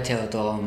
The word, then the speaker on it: Theodora: Aurelia Theodora Mohl. Theodora is the English pronunciation.